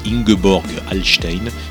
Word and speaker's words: Ingeborg Hallstein: Pour preuve, cette soprano allemande, Ingeborg Hallstein, qui la chante façon opéra dans la langue de Goethe.